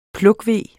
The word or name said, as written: plukkeve